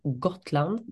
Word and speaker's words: Gotland: going from Gotland, that's the pronunciation, going on to sort of meet the other teams.